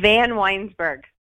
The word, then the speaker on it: Vanwynsberghe: Vanwynsberghe.